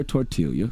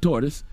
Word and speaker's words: tortilla: And in its most basic form, keyword basic, the San Antonio breakfast taco is nothing more than a flour tortilla filled with refried beans.